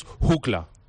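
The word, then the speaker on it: Hucla: Sí, mira, la pronunciación original alemana es JUCLA.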